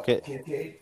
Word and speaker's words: Kincaid: Kincaid.